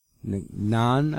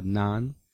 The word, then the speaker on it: Ngan: Also starring Cherry Ngan, Nan, I believe it's pronounced, N-G-A-N, I believe that's spelled Nan.